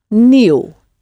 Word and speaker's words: nieuw: Spelled N-I-E, so that's that E sound, but then added to that is a U. And of course, it, like in new, it ends in the W. So it's N-I-E, U-W. New.